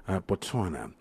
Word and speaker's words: Botswana: So we decided, listen, we're going to go to a beautiful country that's just right next door, Botswana.